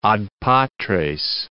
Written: ad patres

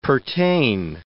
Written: pertain

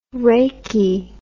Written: rachi

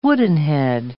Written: woodenhead

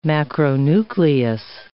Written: macronucleus

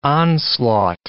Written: onslaught

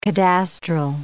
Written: cadastral

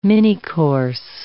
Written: minicourse

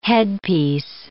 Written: headpiece

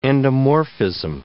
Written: endomorphism

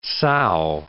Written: sow

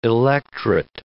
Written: electret

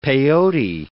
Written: peyote

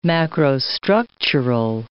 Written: macrostructural

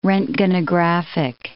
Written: roentgenographic

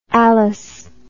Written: alis

